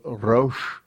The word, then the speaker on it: Resh: And the letter we haven't come across yet, it'll come towards the third to the last letter, is Resh. Resh is, you roll your R's a little bit with Resh, but the word here is Sitri.